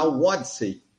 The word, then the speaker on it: Wodyssey: Outra coisa que dá para falar para vocês é que agora sim podemos divulgar, porque já está no ar, na Wodsey que se escreve W-O-D-Y-S-S-E-Y.